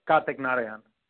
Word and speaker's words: Karthik Narayan: Karthik Narayan.